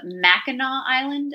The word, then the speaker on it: Mackinac Island: And my husband always talked about, I don't know if I'm going to say this right, but Mackinac Island. – Is yes. – That correct? – Yep.